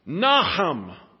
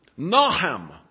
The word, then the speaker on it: Nahum: If I were to pronounce this Hebrew word the way it's supposed to be pronounced, you would hear it such as this: Nahum. Nahum.